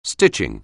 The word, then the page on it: stitching: anglais